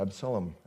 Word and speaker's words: Absalom: Absalom, or Absalom, as my wife pronounces it, was after him, pursuing him.